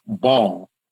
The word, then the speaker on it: Baal: So that logo comes from Baal, which is really pronounced ball. It's B-A-A-L, pronounced ball, which is where we see.